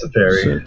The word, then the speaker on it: Teferi: Did I say that right this time? – Yes, Teferi. So, Teferi.